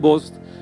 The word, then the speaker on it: Booz: y mucha gente me dice el voz, pero yo siempre pensé en voz como se escribe aquí, en B-O-O-Z, no como el voz de, la ropa.